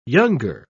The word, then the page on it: younger: British English